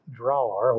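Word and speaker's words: Dragør: The same day in Dralar, which is at least something approaching how the Danes pronounce it, about 6 miles south of Copenhagen, carloads of refugees were cut off by German soldiers as they headed for waiting boats.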